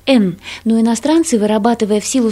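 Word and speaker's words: эн (円): Японцы название своих денег произносят как «эн», а не «йена». Когда-то иероглифы, обозначающие деньги, произносились японцами как «вен».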